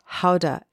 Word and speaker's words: Gouda: My favorites, not mentioned above, include cheeses, like the soft, delectable French cheese Camembert, and also Gouda, pronounced Houda in its native Holland, and Stilton cheese.